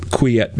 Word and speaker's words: Quillette: Yeah, I was actually going to go back to the social justice thing because this article in Quillette, I want to pronounce it Quillette because it's French, but anyway.